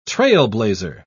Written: trailblazer

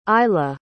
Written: Iyla

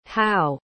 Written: hau

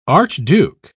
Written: archduke

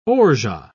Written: orgeat

